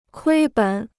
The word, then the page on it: 亏本: kuī běn